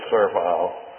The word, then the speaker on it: servile: He is tender without being weak, strong without being coarse, lowly without being servile, It is pronounced servile, you know, not servile. That is, that's the preferable rendering. If you've been saying servile, that's okay. That's secondary. But I always like to use the pronunciation that's given first, don't you? So, servile. He has conviction without intolerance. If you don't learn anything today, you have learned how to pronounce. I started to say servile. But servile.